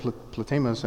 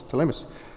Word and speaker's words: Ptolemais: The P is silent there, and I really wanted to say the other word, Ptolemais, and I was like, nope, it's Ptolemais, where we greeted the brothers and sisters and stayed with them for a day.